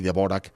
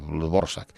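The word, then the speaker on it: Dvořák: De hecho, eh, mm, hay muchas personas que todavía creen que Borsak y deborak son dos compositores distintos. Pues no, lo que pasa es que se escribe de pero se lee Borsak.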